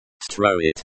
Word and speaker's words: строит: Строит. The O in the first syllable is pronounced like the O in hot. The I in the second syllable is pronounced like the E in C.